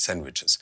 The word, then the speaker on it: sandwiches: Aber das sind Sandwiches und man sagt im Amerikanischen, das D spricht man ja nicht, Sandwiches.